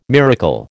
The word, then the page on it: miracle: American English